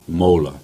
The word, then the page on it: Mawla: Cornish